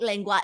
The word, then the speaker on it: lengua: How do I pronounce this? Lengua tacos? – Yeah, lengua.